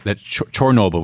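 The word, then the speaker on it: Chornobyl: This is this state or province, this region that Chernobyl, which I've come to understand is the Ukrainian pronunciation for that city.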